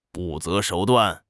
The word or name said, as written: 不择手段